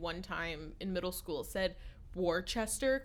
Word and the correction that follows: Worcester: because that's how it's spelled. – But actually, but it's Worcester.